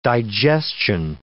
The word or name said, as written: digestion